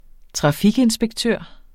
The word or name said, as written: trafikinspektør